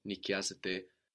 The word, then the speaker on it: νοικιάζεται: Αυτό το σπίτι δεν νοικιάζεται. Δεν νοικιάζεται αυτό το σπίτι. So, again, sounding the same as you plural rent, but spelled with the A-I ending, like the verb είναι, νοικιάζεται.